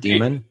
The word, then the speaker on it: daemon: And I guess when I say the word demon, it's actually spelled like D-A-E-M-O-N, like daemon or something. They pronounce it demon.